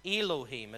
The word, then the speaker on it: Elohim: Elohim. It's spelled E-L-O-H-I-M in the English transliteration, but Elohim.